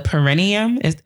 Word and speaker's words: perineum: perineum. Am I saying that right? – Yeah. – The perineum.